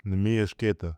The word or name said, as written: Neemias Queta